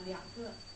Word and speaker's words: 两个: How do you say two? – 两个。